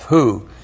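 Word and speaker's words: hu: And then we have the personal pronoun who. H-U, long U, Aleph, who.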